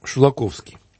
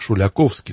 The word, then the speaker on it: Шулаковский: Итак, сегодня мне хотелось бы, чтобы ты рассказал нам о таком замечательном певце, как Леонид Шулаковский. Его иногда почему-то произносят как Шуляковский, но там даже вот сегодня мы будем слушать его, послушать, как произношение русское.